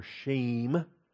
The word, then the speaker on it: Shem: One of the most important Hebrew words you'll ever learn is the name Shem, like Ham, Shem, and Japheth, right? And so Shem or Shame, I prefer to pronounce the E there as a longer A sound.